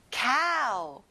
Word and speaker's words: cow: 소, cow. 여기는 입을 크게 벌려보세요. ow 소리를 내야 되거든요.